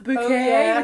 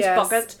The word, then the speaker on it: Bucket: I feel like I'm Hyacinth Bouquet, which is of course, a great British institution, comedy institution lady, posh lady. Her name's Bucket. – Phuket. – She pronounces a bouquet.